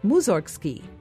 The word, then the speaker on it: Mussorgsky: In Russian, his name is pronounced Mussorgsky, which sounds like it has something to do with cows, but it doesn't.